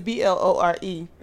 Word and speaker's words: Blore: Which was Blair. Blar. I keep forgetting. – They'd be like, Blore. I was like, how do you spell that? But it's Blore.